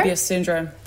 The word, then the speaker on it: Möbius: Möbius syndrome, that's right. – So what is that? – I'll explain it to you. So when Vinny was born, she was the second one ever born with Möbius syndrome, so ever in the world.